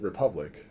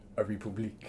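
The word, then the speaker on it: République: And I think you just pronounce it Republic. But in my brain, I'm like, a republique.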